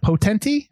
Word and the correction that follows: Potente: I think I said that right. – Potente, yeah. – Potente.